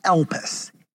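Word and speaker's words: elpis (ἐλπίς): The word for hope in the New Testament is a Greek word that is pronounced elpis, E-L-P-I-S, like Elvis, but elpis, okay?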